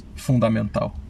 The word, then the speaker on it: fundamental: How do you say that in Portuguese? – Fundamental. – Wow, nice. – Pretty close. Yeah. just the intonation change.